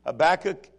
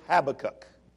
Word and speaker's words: Habakkuk: In English, it's Habakkuk. In Hebrew, it's Habakkuk.